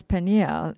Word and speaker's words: Penuel: Now the name of her father, Penuel, if pronounced in Hebrew, is Peniel, and it means the face of God.